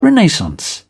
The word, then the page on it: renaissance: British English